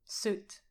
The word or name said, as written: soot